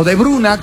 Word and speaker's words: De Bruyne: Kevin De Bruyne o De Bruyne o De Bruna, como correctamente su pronunciación según los belgas. Bruna. Exactamente.